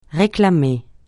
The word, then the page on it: réclamer: francais